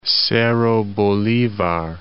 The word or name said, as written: Bolivar, Cerro